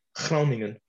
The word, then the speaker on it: Groningen: Can you say Groningen again in that fantastic accent? – Groningen.